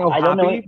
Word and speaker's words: O'Hoppy: I'm going with this Angels kid, Logan O'Hop. I think that's how you say his name. – I'm hearing O'Hoppy, but okay. – Hop or Hoppy.